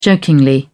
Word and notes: jokingly: British English

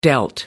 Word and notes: dealt: American English

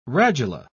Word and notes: radula: British English

